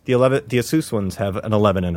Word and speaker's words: Asus: The Asus ones, and it's pronounced Asus, like U-H.